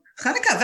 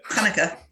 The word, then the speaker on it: Hanukkah: And uh, I discovered that there is a, uh, a, a, a, uh, how do you pronounce it? Hanukkah. I can't. – Hanukkah, very good. Oh, yeah, you. just clear your throat. Yeah. Hanukkah. Yeah, yeah.